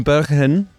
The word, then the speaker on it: Bergen: You're from Bergen in the Netherlands, which is... Yes. Is that how you pronounce it? – Bergen, yes.